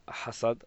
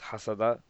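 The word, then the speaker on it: hasad (حسد): In Arabic, it's known as hasad, or pronounced hasada,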